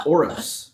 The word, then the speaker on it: Oroz: Like a S or like a Z? – Z. – Oros. – Z. Yeah. – Oros. – Oros. – Yeah. Oros. – Like a rose.